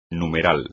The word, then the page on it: numeral: espagnol